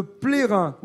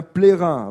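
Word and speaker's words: Ploëren: La commune de Plérin. Oui, on dit bien Plérin. Alors, le nom de la commune s'écrit P-L-O-E-R-E-N, mais se prononce Plérin.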